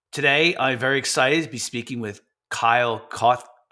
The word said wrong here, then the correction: Cote: Kyle, did I pronounce the last name correct? – It's Cody. – Cody, I'm sorry. So it's Cody. I knew I said it wrong.